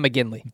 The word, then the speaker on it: McGinley: What the hell is John McGinley. – McGinley. I said McGlinchey. I don't know why I said McGlinchey. I'm thinking of Mike McGlinchey, the offensive lineman. John, thank you. Thank you very much. John McGinley.